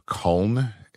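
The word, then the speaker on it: Culnn: Anyway, the new Lord High Commander is a guy named Carib Culn. There's no I in it. It's C-U-L-N-N.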